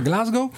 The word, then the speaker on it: Glasgow: Una notizia che interesserà gli zeppofili è che è stato scoperto un audio inedito di un concerto del 73 a Glasgow, Glasgow, non me ne vogliano i locali che mi correggono sempre quando sbaglio la pronuncia, Glasgow.